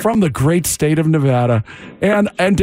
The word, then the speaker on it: Nevada: Point in Nevada. Okay. – Nevada. It's Nevada, sir. Nevada. Please say it correctly. It's Nevada.